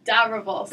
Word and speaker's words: Dobrowolski: What is the worst pronunciation of your last name that you've heard? – I hear people don't even add the R. It's like Dobrowolski or something. It's just all over the place, like over the announcements at school and stuff and guest teachers, but it's all cool.